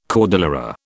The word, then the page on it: cordillera: British English